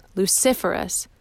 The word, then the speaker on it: luciferous: Today's word is luciferous, and Zay pronounces it wrong. No, Miss Adler says, luciferous.